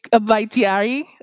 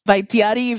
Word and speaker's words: Vaitiari: I will pronounce in my best Cuban accent my Tahitian name. It's Vaitiari Rodriguez. Vaitiari, if you say it in, in, in like I say Cuban Spanish.